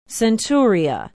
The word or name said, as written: centuria